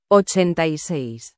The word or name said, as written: 86